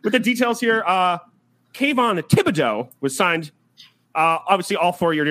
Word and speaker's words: Thibodeau: Thibodeau. The Dow. Get it out. Dow.